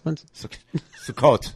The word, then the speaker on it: Sukkot: That's Sukkos? – Sukkot. Sukkot. – Yeah, don't tell me how to pronounce it.